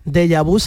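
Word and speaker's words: Deyabú: Deja Vu se escribe D-E-Y-A y con la U con acento. Deja Vu y la U con acento.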